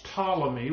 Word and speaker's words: Ptolemy: I know it has a P at the beginning of it, but it's pronounced without the P. It's Ptolemy was given Egypt, the Ptolemaic dynasty that went through that period for some 300 years.